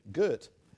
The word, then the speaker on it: girt: Girt. rhymes with dirt.